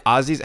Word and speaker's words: Aussies: Now you Aussies, am I saying that right? Aussies? How do I say it? I always say it wrong. That's better. The second way is better.